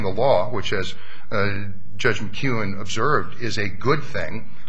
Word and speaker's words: McKeown: And Judge McKeon easily dispatched and distinguished. – McKeown. – I'm sorry, McKeown.